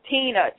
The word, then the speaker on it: Tina: It is Tina, and my date of birth is 8/29/74. – What did you say your name was again? – Tina, T-I-N-A.